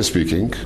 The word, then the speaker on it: speaking: Никогда не произносите на конце. Если вы очень сильно хотите, надо там сделать так называемый насальный звук. Speaking, speaking, но это не speaking, никогда, никогда.